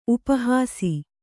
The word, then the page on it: ಉಪಹಾಸಿ: upa hāsi